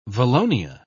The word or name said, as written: valonia